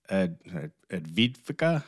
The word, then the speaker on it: Avdiivka: This happens at the same time that Russia just took a major city in Ukraine, Avdiivka, I believe it's called.